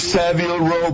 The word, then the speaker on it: Savile Row: It's Savile Row, baby.